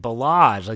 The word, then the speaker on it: Ballage: But nonetheless, I thought Ballage, gosh darn it, I mispronounced his name again. Ballage, like the Bellagio in Vegas.